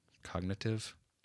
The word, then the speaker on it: Cognitif: But the guy is called Cognitive, but it's spelled C O G N I T I F.